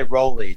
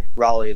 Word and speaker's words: Raleigh: Yeah, and a lot of people say Rolly too, but Raleigh, like North Carolina.